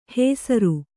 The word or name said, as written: ಹೆಸರು